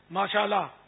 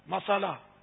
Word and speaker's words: ماشاءاللہ: تو وہ آج کل کے تلفظ کے ساتھ کہے گا تو شاید فائدہ نہیں ہوگا, جیسے ماشاءاللہ مسالہ اس سے تو کوئی فائدہ نہیں ہوگا. ماشاء اللہ ماشاء اللہ ماشاء اللہ صلوا علی الحبیب.